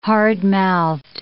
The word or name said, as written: hardmouthed